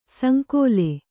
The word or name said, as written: ಸಂಕುಲ